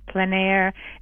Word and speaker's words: plein air: Yes, I suppose it's pronounced a couple different ways. Plein air. It's a French term, meaning to, I guess, in essence, paint outside in the moment.